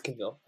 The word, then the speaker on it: Cavill: I think I'm butchering his name, but he has like these Henry yellow Cavill. Contacts. – No, it's Cavill. Cavill.